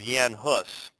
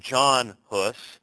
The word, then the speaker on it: Jan Hus: in the person of Jan Hus. And some of you have heard the name John Hus. I'm just using the pronunciation that he would have been familiar with.